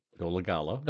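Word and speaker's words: Dolegala: Saskatchewan quarterback Jake Dolagala. – Dolagala. So it is, okay, I feel like I said it wrong every time.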